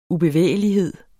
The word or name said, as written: ubevægelighed